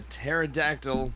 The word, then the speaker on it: pterodactyl: Joke is, why can't you hear a pterodactyl go to the bathroom? – Why? – Because the P is silent.